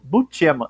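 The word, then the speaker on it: Buscema: A gente perguntou para ele qual era a pronúncia correta. E ele, como humilde que ele é, disse: Eu falo Bucema. É, Bucema. Quer dizer, eu falo. Ele nem disse que é o correto.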